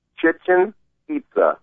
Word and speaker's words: Chichen Itza: Chichen Itza, like chicken pizza.